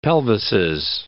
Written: pelvises

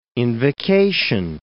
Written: invocation

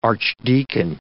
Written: archdeacon

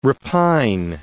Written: repine

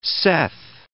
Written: Seth

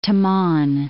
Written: Taman'

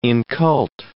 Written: incult

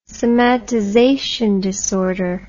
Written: somatization disorder

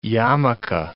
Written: yarmulke

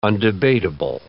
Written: undebatable

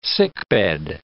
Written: sickbed

